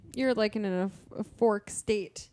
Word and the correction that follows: euphoric: A fork state? – Euphoric. That's what I meant.